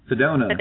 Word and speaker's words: Sedona: Sedona.